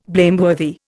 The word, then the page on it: blameworthy: Indian